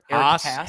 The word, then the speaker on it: Haas: Use your fastball to not throw down the dick to White Sox killer Eric Haas. Am I pronouncing that correct? – Haas.